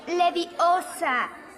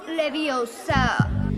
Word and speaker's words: Leviosa: No se dice así. Es Leviosa, no Leviosa.